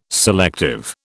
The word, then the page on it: selective: British English